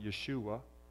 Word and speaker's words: Yeshua: The word Jesus is the Greek translation of the Hebrew word Joshua, or as the Hebrew people would pronounce it, Yeshua.